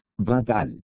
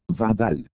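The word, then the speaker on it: Vadal (בָּדַל): No sé si alguna vez la han buscado, pero la primera es, Vadal. Vadal, es como se escribe fonéticamente.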